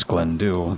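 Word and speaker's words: Glendhu: It is right on the bank of Loch Glendu, pronounced as Glendu, the Gaelic translates to English as Black Glen.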